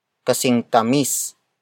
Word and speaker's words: kasing tamis: Word for word it's pinyang kasing tamis nang pulot.